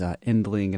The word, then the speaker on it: Yndling: That is Endling, and it's spelled Y-N-D-L-I-N-G.